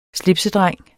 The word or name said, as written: slipsedreng